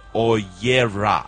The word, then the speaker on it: Oyera: Your new song is called Oyera. I hope I'm pronouncing it correctly. – Yes, very correctly. – Oyera.